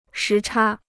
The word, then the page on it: 时差: shí chā